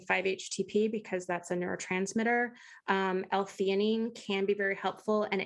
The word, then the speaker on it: L-theanine: We started with 0.5 milligram of melatonin 2 months ago and are wondering about L-theanine, is how I think you pronounce that, and 5-HTP. – I'm pretty sure it's theanine, but yeah.